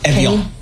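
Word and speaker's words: Évian: Evian. – Like the water.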